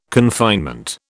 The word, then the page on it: confinement: British English